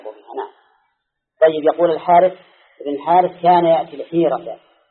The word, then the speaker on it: الحارث: لا ابن الحارث، لكن هم مثل الحارث ما يحطون فيها ألف، هذا ينطق بالألف ولا تكتب، مثل هذا أي هذا فيها ألف بين الأبناء والزال، نطقا فيها ألف، لكن كتابة الحاق فيها ألف نطقا لا كتابة.